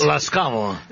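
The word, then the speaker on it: Laskavo (Ласкаво): Laskavo.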